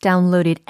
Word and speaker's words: download: 다운로드에 해당하는 영어 단어의 발음이 다운로드가 아니라 download, download 하고 계시죠? download. 이렇게 발음을 하셔야 되고, 어, 수동태로서 뒤에 pp형이니까 ed가 붙어서 발음이 downloaded, downloaded 이렇게 발음을 하셔야 됩니다.